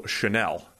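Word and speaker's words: Chenal: Leo Chanel, I've been told. – It is. – Is the pronunciation. – Weirdly. Feels like if you wanted to be pronounced Chanel, you should spell it Chanel.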